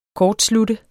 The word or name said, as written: kortslutte